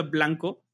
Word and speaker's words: BLNKO: Yo ahorita estoy escuchando mucho a un, un artista que se llama, creo que se pronuncia Blanco, es BLNKO, así sin, sin vocales más que la última O.